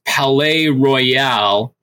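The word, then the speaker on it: Palaye Royale: Palais Royal, them. Yeah. – You're saying it right.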